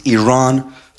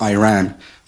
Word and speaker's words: Iran: hlustum að það sá þetta bara You say you support the Iranian people but you're pronouncing our country's name wrong Our country is named Iran, not Iran Please once and for all,